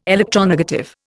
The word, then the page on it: electronegative: Indian